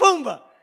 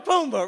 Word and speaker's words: Pumbaa: Pomona Tuma. What, how you say his name? Say it again, say it again. Timon and Pumbaa. I said Pumbaa and somebody else. Timon and Pumbaa, right? There you go.